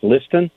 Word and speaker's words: Liston: My name is pronounced Liston, but it looks like Listen. So people say, man, you got a cool name, Jackie Listen, but it's actually Jackie Liston.